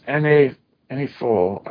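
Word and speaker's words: années folles: It's at Anise Falls, pronounced Anifol.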